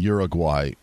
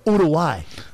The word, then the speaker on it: Uruguay: I love uh, the fact that you are committed to the American pronunciation, right? Like some guys come on and go, Uruguay, Uruguay, you know, Portugal. I, I Uruguay.